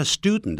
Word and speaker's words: student: 顺便谈一下 student 的发音呢，在美式的英语里头啊，常用鼻音出现的，像有 done 有 ton 这样的音都用鼻音啊，听起来有点像我们讲的比较难听一点，上大号的时候上厕所的那个鼻音，不过嘴巴张开的啊。